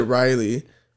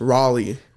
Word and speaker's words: Raleigh: No, because I said Raleigh and you said Riley, but it's Raleigh. – Raleigh. Okay. Anyways. Raleigh. – Okay, now if you ever go to Raleigh, North Carolina, now you know how to say it the right way.